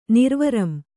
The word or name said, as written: ನಿರ್ವರಂ